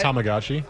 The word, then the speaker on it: Tamagotchi: It's Tamagotchi. – Tamagotchi.